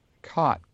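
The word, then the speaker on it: qat: Cot, Q-U-A-T, pronounced cot, is a bush that grows in and around the Arabian Peninsula.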